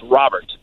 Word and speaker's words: Robert: That is the official pronunciation that the White Sox are giving us now. It's Robert.